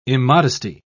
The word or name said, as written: immodesty